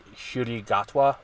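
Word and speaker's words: Ncuti Gatwa: According to a pronunciation guide, Shurigatwa is how you pronounce it.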